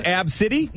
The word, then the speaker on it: ABCDE: The girl who named her daughter ABCDE. – Oh, yeah. – Pronounced Ab City.